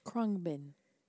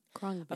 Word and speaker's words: Khruangbin: Krungbin. – What? – Krungbin. – It's K-H-R-U-A-N-G-B-I-N. – Krungbin.